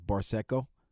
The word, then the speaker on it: Barsecco: But it's spelled Barsecco. So I'm thinking it's Barseco. – Yeah, sounds right to me.